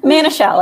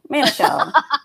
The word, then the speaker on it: Manischel: Manischel, like man, it's a shell. Manischel.